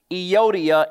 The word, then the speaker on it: Euodia: Dear friends, verse 2 I urge Euodia, and I urge Syntyche, yes, those are correct pronunciations of those words, to agree in the Lord.